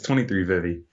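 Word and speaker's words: 23Vivi: Is it 2366? – I like that name, but it's 23 Vivi.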